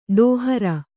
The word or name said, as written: ಡೋಹರ